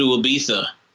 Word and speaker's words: Ibiza: maybe go to Ibiza. European from the continent of Europe. We call it Ibiza, even though there's a Z. – Right. – Us in the know use a TH sound.